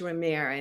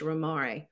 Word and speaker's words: Romare: But it's not pronounced, it was never really pronounced Romare. And in a couple of oral interviews, he spelled it phonetically as Romare. Most people called him Romy, and so I'm just going to call him Romy as I talk about him.